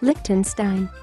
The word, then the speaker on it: Liechtenstein: Espera, no lo pude pronunciar, así que he llamado a alguien para que me ayude con la pronunciación. Liechtenstein.